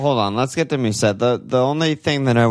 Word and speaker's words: Mousset: I'd rather have Mousset, not close, for a million less. – Mousset, I don't think, dude, that's. Don't say that. Don't pronounce that. T is French, dude.